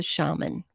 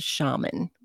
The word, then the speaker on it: shaman: Some people say shaman, and some people say shaman. So shaman, I found out, is the U.S. or the American pronunciation, where shaman is the British English pronunciation. Both are correct if you do hear people say that.